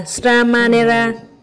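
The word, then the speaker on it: Śrāmaṇera: Nên nếu dùng phạn văn mà đọc là Sramanera, tức là ý nghĩa của sa di, dịch thành trung văn, Ngài dịch thành cần sách nam.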